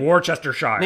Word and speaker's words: Worcestershire: How do you say that word? – Isn't it Worcestershire? – Sure. – Yeah, Worcestershire. – I feel like it has more letters than that.